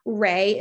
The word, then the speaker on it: Rhea: Ray is R-H-E-A, and then services.